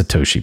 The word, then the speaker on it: Satoshi: And yes, I can say I can say it correctly. It's Satoshi, by the way.